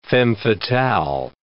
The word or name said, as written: femme fatale